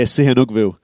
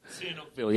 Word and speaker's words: Sihanoukville: How do you pronounce Sihanoukville? – Yeah, Sihanoukville. – Sihanoukville, yeah, I knew I get it right.